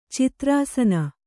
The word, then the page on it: ಚಿತ್ರಾಸನ: Kannada